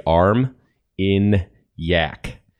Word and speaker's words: Armagnac: So if you want to learn how to pronounce that if this is going to sound terrible, say arm in yak. That sounds inappropriate. It sounds like you're doing something to a yak. But that being said, that's how it's pronounced.